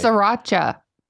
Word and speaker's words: Sriracha: Sriracha, S-R-I. – But it's pronounced Sriracha.